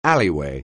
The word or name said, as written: alleyway